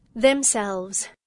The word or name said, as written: themselves